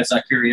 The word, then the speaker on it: Zakir: Zakir? – Yeah, Zakir, yeah.